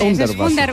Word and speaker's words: Hundertwasser: Me lo han corregido antes, Hundertwasser. – Es Hundertwasser. Sí, sí, Víctor. – Víctor que sabe alemán, sí, inglés, italiano. – Sí, me ha dicho Hundertwasser.